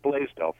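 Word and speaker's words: Blasdell: It's pronounced Blaisdell, Frank. – All right. – Nice. Welcome. – B-L-A-S.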